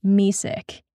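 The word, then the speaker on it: Mesick: The first thing you should know about Messick, Michigan is that it's pronounced Messick, like, sorry, I can't have any lactose because it makes me sick.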